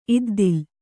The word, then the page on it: ಇದ್ದಿಲ್: iddil